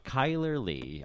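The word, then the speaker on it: Chyler Leigh: Kyler Lee. I've always, the name Lee, Leigh, I've always wanted to pronounce Lay. Or lie, but lie. – No one does.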